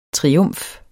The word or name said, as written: triumf